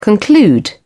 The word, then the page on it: conclude: British English